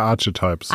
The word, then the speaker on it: Archetypes: Ja, Archetypes.